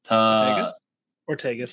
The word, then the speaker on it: Ortegas: Ortegas. – Say again? – Is it Ortegas? – Yeah.